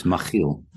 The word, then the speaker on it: Machiel: Yes, it's a Dutch name. So the CH is pronounced as a ch. And so it's Machiel.